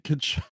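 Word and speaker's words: Tkachuk: Saying that he kept pronouncing the T. The silent T in Kachuk.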